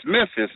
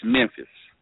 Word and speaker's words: Memphis: Okay, well, see now, it's Memphis, but if you down here, it's Memphis. Memphis. – Now, and there is a North Memphis.